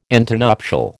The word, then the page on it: antenuptial: American English